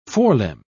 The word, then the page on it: forelimb: British English